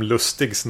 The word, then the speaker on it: Lustig: Visst blir det alltid så att man uttalar William Lustigs namn med extremt svensk eh, ja. uttal. Jag vet inte riktigt hur man ska... Hur, hur uttalar han Lustig? Lustig, ja.